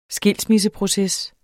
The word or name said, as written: skilsmisseproces